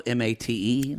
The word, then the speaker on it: mate: It's spelled mate. That's mate. Yerba mate. – You know, words from other countries are pronounced differently.